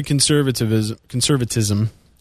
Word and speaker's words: conservatism: Well, going back to conservatism, I always want to put an extra syllable in there. Conservatism.